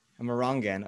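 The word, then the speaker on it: Amerongen: Amarongen. – Very close. Amarongen. – Amarongen, of course, the proper Dutch pronunciation.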